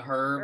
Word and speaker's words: herb: A herb? – Herb, yeah. – An herb. Is it herb or herb? – Herb. The H herb? Is silent. Herb.